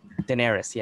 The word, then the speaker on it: Daenerys: Daenerys.